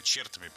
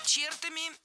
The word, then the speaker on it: чертами: Какими чертами писатели, да, наделяли зло? Вот сквозь века. – Чертами? Неправильно. Правильно, чертами. Опять же, перед нами слово с ударением на окончании во всех формах.